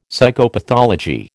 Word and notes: Psychopathology: American English

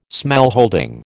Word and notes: smallholding: American English

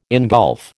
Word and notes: ingulf: American English

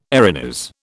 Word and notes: Erinys: British English

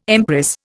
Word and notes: empress: Indian

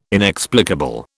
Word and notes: inexplicable: British English

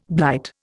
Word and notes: blight: Indian

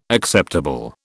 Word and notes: acceptable: British English

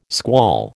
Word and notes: squall: American English

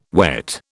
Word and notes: wet: British English

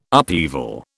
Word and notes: upheaval: British English

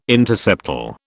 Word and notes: interseptal: British English

